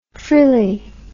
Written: frillie

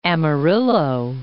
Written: Amarillo

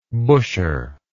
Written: busher

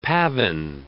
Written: pavan

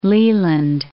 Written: Leland

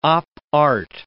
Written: op art

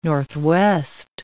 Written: northwest

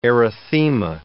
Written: erythema

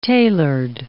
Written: tailored

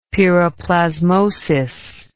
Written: piroplasmosis

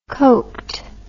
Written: coked

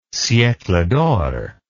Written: siecle d'or

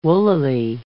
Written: woollily

